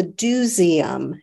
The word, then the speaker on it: DoSeum: and then also the Dooseum, and that's spelled D-O-S-E-U-M, as in let's go do something, and that's a hands-on kids museum.